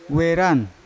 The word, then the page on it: weran: Yawa